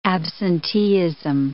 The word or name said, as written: absenteeism